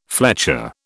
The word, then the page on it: fletcher: British English